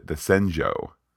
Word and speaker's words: Shenzhou: So she then builds on that and says, let's build a bridge to get on over to the Shenzhou, or rather the Senzhou. I'm pronouncing it more properly, Pete.